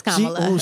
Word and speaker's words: Kamala: Is it Kamala? – It's Kamala.